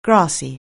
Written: grassy

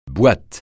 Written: boîte

